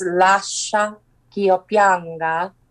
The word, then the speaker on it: Lascia ch'io pianga: it's in Italian, and the pronunciation is Lascia ch'io pianga, and it's from the opera Rinaldo.